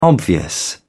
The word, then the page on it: obvious: British English